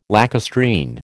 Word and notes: lacustrine: American English

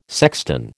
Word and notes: sexton: American English

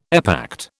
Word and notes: epact: British English

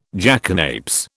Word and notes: jackanapes: British English

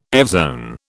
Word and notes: evzone: British English

